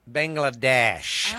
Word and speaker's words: Bangladesh: Bangladesh.